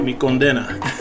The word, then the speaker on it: Condena: Not the other way around. Not the not with the emphasis on the D. It's emphasis on the A. Mi Condena.